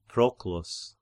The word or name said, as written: Proclus